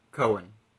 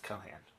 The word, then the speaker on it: Cohan: Cohen. – I believe it's pronounced Cohan.